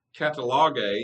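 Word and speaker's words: katallagē: The New Testament word, which we would pronounce catalogue, means sort of the same thing.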